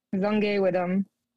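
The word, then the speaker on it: Zongewi'bam: Z-O-N-G E-W-I apostrophe B-A-M. – B-A-M. And pronounce it one more time. – Zongay Widom.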